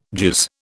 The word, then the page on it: jizz: British English